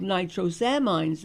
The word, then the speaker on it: nitrosamine: In a recall notice on March 22nd, Ascend Laboratories said it is recalling dabigatran etexalate capsules at the consumer level after nitrosamine, how would you pronounce it? – Nitrosamine. Right, yeah.